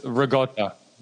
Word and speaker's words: ricotta: You guys were killing me the way you say ricotta. I mean, come on. – What are you talking about? That's how you say it. – John, it's ricotta.